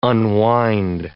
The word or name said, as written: unwind